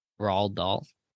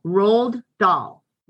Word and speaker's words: Roald Dahl: Now, how do you say his name? – Roald Dahl. – Roald Dahl. – Roald Dahl, right? – That's how I say it.